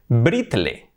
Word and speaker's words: brittle: Buscamos patrones con esta terminación, y es muy, muy común esta palabra. Brittle, brittle, brittle, brittle, ¿cómo acaban estas palabras? I-T-T-L-E, tenemos nuestra amiga little, little, pequeño, chiquitín, que sería little, little.